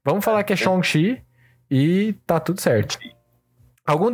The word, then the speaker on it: Shang-Chi: Shang estão falando que a pronúncia na verdade é Shang-Chi.